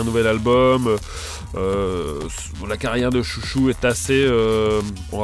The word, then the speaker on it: Xiu Xiu: C'est Eugene Robinson, bien sûr, qui s'est accoquiné à Jamie Stewart de Chouchou. – Alors, c'est Chouchou, mais en fait, ça s'écrit Xiu Xiu, c'est ça ?